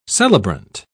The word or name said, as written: celebrant